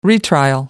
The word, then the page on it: retrial: American English